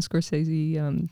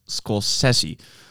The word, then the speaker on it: Scorsese: Data's very beloved Martin Scorsese. – It's pronounced Scorsese.